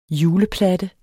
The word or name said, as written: juleplatte